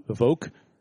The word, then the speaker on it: Evoque: And of course, the first one was the Evoque.